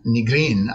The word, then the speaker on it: Nigrin: So, Nigrin, I suppose is the really correct pronunciation.